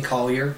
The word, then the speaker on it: Collier: I mean, you think here my name is my last name is pretty easy, Collier.